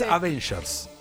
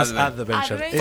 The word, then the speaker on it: Avengers: Es Avengers, no Adventures.